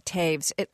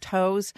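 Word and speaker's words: Toews: I've written a little bit about Miriam Taves. Her name looks like it's pronounced Toes, but I've written about her because of this book that is getting a lot of press called Women Talking.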